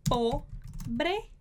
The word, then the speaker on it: pobre: Então, começa com o pobre, pobre, ro, esse som aqui da garganta, ro, se, pobre José.